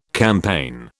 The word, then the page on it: campaign: British English